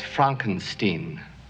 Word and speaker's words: Frankenstein: That's Frankenstein. – I beg your pardon? – My name, it's pronounced Frankenstein.